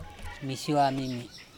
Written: mishua mimi

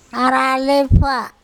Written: aralepha

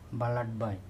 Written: balaTbai

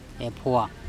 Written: epHoa

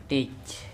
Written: tec

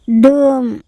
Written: Dum

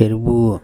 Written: erbuo

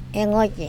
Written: engOche

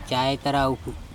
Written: caetaraupHu

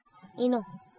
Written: ino

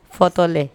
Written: pHotole

